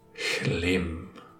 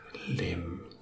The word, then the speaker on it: Chlim: Et le H se prononce comme une expiration. Chlim. Une expiration avant de continuer sur le lim. Chlim.